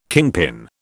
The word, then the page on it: kingpin: British English